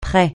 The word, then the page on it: près: francais